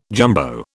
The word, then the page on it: jumbo: British English